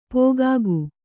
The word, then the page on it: ಪೋಗಾಗು: pōgāgu